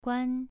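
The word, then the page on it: guan: tone 2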